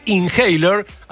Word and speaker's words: Inhaler: Cada vez que presento esta banda, no puedo obviar el detalle de que están liderados por el hijo del cantante de U2, los Inhaler, así se pronuncia esta banda irlandesa.